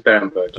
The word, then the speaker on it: Berenberg: I hope you pronounced Berenberg right. – Hi. Yes, thanks for having me on. You said it exactly right. It is Berenberg.